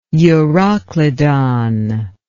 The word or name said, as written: euroclydon